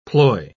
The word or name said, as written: ploy